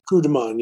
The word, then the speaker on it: coup de main: it's coup de main. Is that right? Am I saying it right? – Coup de main, yeah.